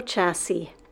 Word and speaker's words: chassis: Number four, auto chassis. Auto chassis. This word is spelled C H A S S I S. And we don't pronounce that S unless it's plural. So if we're just talking about one, it's the same spelling.